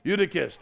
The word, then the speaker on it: Eutychus: It's one of those really kind of Euthychus. Euthychus. I don't know how you say his name. Eutychus. Thank you. You're a Bible scholar. Well done. Eutychus. There we go.